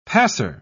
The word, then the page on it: passer: British English